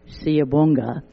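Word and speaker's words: Siyabonga: Thank you, pronounced Siabonga.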